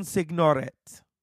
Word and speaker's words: Signoret: Simone Signoret.